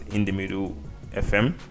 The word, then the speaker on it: InTheMiddleFM: Did I say it correctly this time around? – I think you did. – I did not say In The Middle FM like when you said I did. – Yeah, you said it correctly.